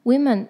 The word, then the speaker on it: women: 来 gave in 呢，它是屈服，那 temptation 我们刚刚说过是诱惑， women 就是 woman 的复数，注意哦，单数跟复数的发音不大一样，女人的单数是 woman，但是它的复数是 women，大家注意一下。